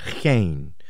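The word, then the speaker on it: chen (חֵן): But this word grace here is the Hebrew word, chen, I believe is how you would pronounce it, chen.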